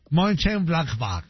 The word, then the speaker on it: Mönchengladbach: Del Mönchengladbach, así exacto, así como lo pronunciaría, así es. Así va el alemán. – Mönchengladbach. – Así, así. Exactamente.